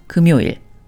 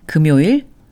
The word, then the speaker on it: 금요일: 금 followed by 요일, 금요일 is pronounced 금요일, which is Friday. 금요일, 금요일, 금요일.